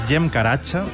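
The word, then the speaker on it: Cem Karaca: Este es Jem Caracha, que se escribe Zem Caracas, si lo queréis buscar.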